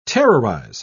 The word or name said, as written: terrorize